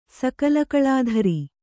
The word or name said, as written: ಸಕಲಕಲಾಧರೆ